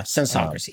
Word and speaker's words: sensocracy: So this, so what we're saying is that the evolution of technology will create this sensocracy. Is that how you say it? – Yeah, sensocracy, yeah. – Sensocracy. – Like census sensocracy, yeah.